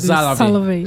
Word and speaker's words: Salovey: Salovey.